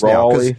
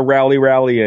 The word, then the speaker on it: Raleigh: You've learned how to say it. – I've had enough practice Raleigh. – Now. – It's not a rally anymore. – That's it's a Raleigh rally.